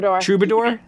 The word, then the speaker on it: Troubadour: Troubadour? Am I saying that right? – Troubadour.